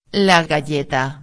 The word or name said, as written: la galleta